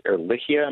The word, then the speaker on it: Ehrlichia: The vet has subsequently, we've had several conversations with the vet about Lyme disease and about, I think it's Ehrlichia. Am I saying that right? – Yes, you are.